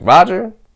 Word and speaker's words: Rodger: I don't know why you call me Roger. But that's cool. That's fine. You can just keep calling me Roger. So Roger stuck. But I told him we gotta spell it differently. So I spell it R-O-D-G-E-R.